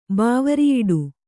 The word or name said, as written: ಬಾವರಿಯಿಡು